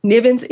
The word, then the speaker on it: Nivens: Nivens is N I V E N S.